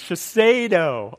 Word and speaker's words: Shiseido: How do you pronounce it? Somebody help me. Shiseido? Is that how you pronounce it? Thank you. I get a nod from a guy out here. Thank you very much. That's how you pronounce this. Shiseido.